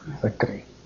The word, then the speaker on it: Sakari: Sakri. – Can you spell that, please? – S-A-K-I-R-I. S-A-K-A-R-I, Sakri.